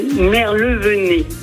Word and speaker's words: Merlevenez: Comment on prononce votre commune? Je ne voudrais pas faire d'impair. – Merlevenez. Voilà, c'est ça.